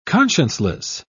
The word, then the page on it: conscienceless: British English